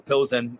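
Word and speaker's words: Plzeň: But obviously, from a player's perspective, really quickly, because we saw the earlier kickoff today where Inter Milan absolutely smashed Plzenia, or Pilsen, however you want to pronounce it there.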